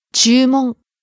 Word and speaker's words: 注文: 注文。 – Order, request. – 注文。注文。 – Starting low, rising on the second syllable, う。 Staying high.